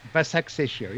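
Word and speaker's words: Başakşehir: Basaksehir, yeah.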